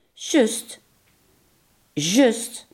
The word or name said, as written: zojuist